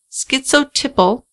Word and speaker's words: schizotypal: His lawyer has stated that Hernandez was diagnosed with schizotypal.